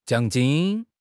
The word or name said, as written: jiang3 jin1